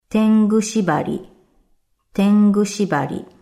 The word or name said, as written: Tengu shibari